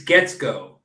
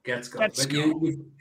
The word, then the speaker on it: Guetzkow: Yeah, I mean, the original German would be Guetzkow, but it was Americanized, but they never changed the spelling, so it just throws everybody off.